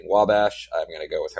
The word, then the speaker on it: Wabash: How do you say that? – Yeah, Wabash.